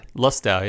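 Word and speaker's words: Lustau: And then also picked up, got a bottle today, got a bottle of red breast Lestau. Is that how you say it? – Lestau. Yeah.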